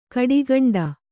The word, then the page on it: ಗಿಡಗಂಟೆ: Kannada